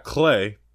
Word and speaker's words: Cleigh: We've got Clay, but it's spelled C-L-E-I-G-H, which I've never seen Clay spelled that way.